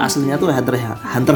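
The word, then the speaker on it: Hunter × Hunter: Hunter x Hunter guys. – Oke, Hunter x Hunter. Itu tuh kalau di versi apa ya? internasional internasionalnya tuh bacanya Hunter Hunter sebenarnya.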